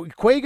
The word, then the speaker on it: Kwayga: Now, Kwayga is spelled K-W-A-Y-G-A, but it's obviously a play on 50, is it? – Exactly. It is the Irish word for 50. There's 50 million companies in the world that should be traded across borders, but are not, according to the World Bank.